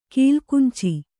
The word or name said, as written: ಕಲಗಂಚು